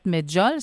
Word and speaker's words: Medjool: C'est dans les années 50 que la date Medjool, c'est comme ça qu'on la prononce? – Oui.